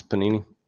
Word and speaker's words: Panini: Is that how you say it, right? Panini? – Yes, Panini.